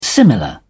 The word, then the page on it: similar: British English